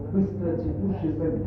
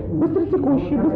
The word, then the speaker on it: быстротекущий: Раньше лучше, если скажем, быстро текущий мужчина. Это будет обеспечено. – Ну и правильно сейчас. Ну, Виктор, неправильно. Быстротекущий. Нет, неправильно. Разделяем неправильно, надо говорить вместе. Быстротекущий. С одним ударением, обязательно с одним ударением.